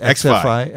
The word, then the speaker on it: XFi: This XFi, is that how you pronounce it? – XFi.